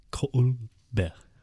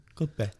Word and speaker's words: Colbert: Yeah, I guess I the T we, silent. It's pronounced Colbert. Colbert. Colbert.